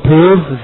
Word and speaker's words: prove: To be angry with a weak person is to prove that you are not strong yourself. 我们把几个比较难的单词来读一下。 We have to say strong. Strong. Person. Person. Prove. 好，注意发音 prove. 咬下唇 prove.